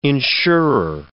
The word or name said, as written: insurer